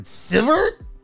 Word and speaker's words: Sivert: S-I-V-E-R-T.